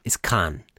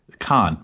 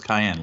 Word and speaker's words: Caen: The big one, I'll tell you, which I got a lot of messages about, was the pronunciation of C-A-E-N, Nico Goacini's club. It's Khan. – Khan, right. Yeah. I think I was trying to get there, and then you immediately were like, no, it's cayenne, like the pepper.